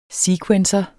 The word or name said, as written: sequencer